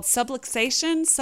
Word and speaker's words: subluxation: Okay, you used a word called subluxation.